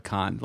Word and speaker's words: Cannes: We we went to the Cannes Film Festival. That's how you say it, by the way. It's not Cannes.